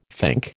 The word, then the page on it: fink: American English